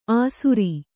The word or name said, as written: ಅಸುರೀ